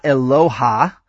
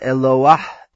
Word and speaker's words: Eloah: Also, note that the ah underneath the last syllable of Eloah is like the ah of Noah. It comes before the guttural last letter. So it's not Eloha, but Eloah, Eloah.